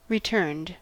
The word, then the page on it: returned: American English